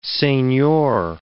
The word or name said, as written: seignior